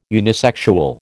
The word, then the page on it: unisexual: American English